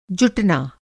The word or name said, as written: जुटना